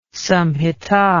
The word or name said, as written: samhita